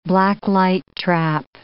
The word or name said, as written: blacklight trap